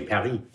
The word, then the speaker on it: Paris: So if I say Paris. Oui, Paris. – Paris.